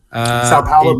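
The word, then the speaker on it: São Paulo: And the city of, say, I don't know if I'm saying this right, Sao Paulo? – Sao Paulo, Brazil, yeah.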